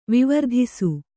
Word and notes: ವಿವರ್ಧಿಸು: vivardhisu

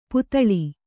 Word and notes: ಪುತಳಿ: putaḷi

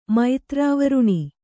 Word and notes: ಮೈತ್ರಾವರುಣ: maitrāvaruṇa

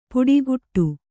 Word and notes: ಪುಡಿಗುಟ್ಟು: puḍiguṭṭu

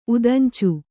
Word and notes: ಉದಂಚು: udañcu

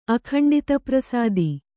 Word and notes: ಅಖಂಡಿತಪ್ರಸಾದಿ: kannada

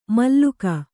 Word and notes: ಮಲ್ಲುಕ: malluka

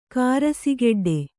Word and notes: ಕಾರಸಿಗೆಡ್ಡೆ: kannada

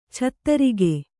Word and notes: ಛತ್ತರಿಗೆ: Kannada

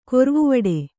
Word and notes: ಗೊರವವಾಡ: kannada

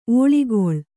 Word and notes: ಒಳ್ಗಾಲ: Kannada

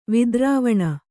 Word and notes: ವಿದ್ರವಣ: vidravaṇa

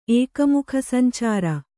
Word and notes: ಏಕಮುಖಸಂಚಾರ: Kannada

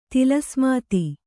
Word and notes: ತಿಲಸ್ಮಾತಿ: tila smāti

